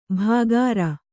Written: ಭಾಗಾರ

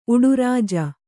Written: ಉಡುರಾಜ

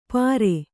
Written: ಪರೆ

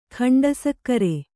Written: ಖಂಡಸಕ್ಕರೆ

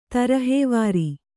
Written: ತರಹೆವಾರಿ

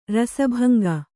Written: ರಸಭಂಗ

